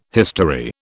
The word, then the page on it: history: British English